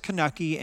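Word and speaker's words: Kanuchi: And I know there's different pronunciations, so don't write in on that one. We know there are different pronunciations. But to him, it's Kanucky, and Kanucky is often hickory nut and acorn and then whatever base nut, so whether it's pecan or whatever.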